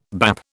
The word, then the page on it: bap: British English